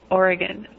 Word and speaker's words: Oregon: Yeah, think of it like this. Oregun.